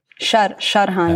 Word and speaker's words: Sharhan: Sharhan.